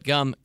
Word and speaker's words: Jumm: It's spelled J-U-M-M, but Gum kindly lets me know that it's actually pronounced like gum.